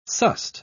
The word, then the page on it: sussed: British English